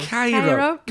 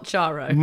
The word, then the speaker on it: Cheiro: Now I can't stress this enough. Her name? – Chiaro. – Cairo. Cairo. It's spelled C-H-E-I-R-O. – Not Charo. – Not, crucially, not Charo.